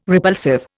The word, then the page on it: repulsive: Indian